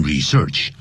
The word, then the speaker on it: research: According to a recent study，可以放在句首，根据最新的一项研究啊，你千万不要用 according to a recent research，那个 research 前面你也可以用，但前面不能加 research 是不可数，也不要念成 research 重音在第一音节。